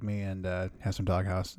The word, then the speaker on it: Dog Haus: Sp- it's spelled uh, like a German house, like H A U S. There you go. That's that's how it's supposed to be.